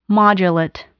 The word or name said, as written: modulate